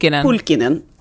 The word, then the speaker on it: Pulkkinen: Han har rekommenderat en författare som heter Rika Pulkkinen. Är det rätt uttal? – Pulkkinen.